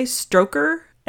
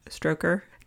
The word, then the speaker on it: Strokkur: The nearby Stroker, S-T-R-O-K-K-U-R, Stroker, geyser erupts every 5 to 8 minutes to a height of around 30 meters or 98 feet.